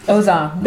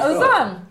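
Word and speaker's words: Aux armes: So Ozam is to eight. Arms. – No. Okay. – Ozam? – Ozam. – That's yeah. our motto, maybe? – Huh? Oh, yeah, I like it. – Ooh. – Yeah, Ozam. – How do you spell it? – A-U-X. – Uh-huh. – And then A-R-M-E-S.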